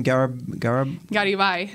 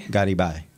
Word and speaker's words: Garibay: Say his name for me again. – Garibay. – Garibay. Okay, good. – But you can say it in English, too. Garibay.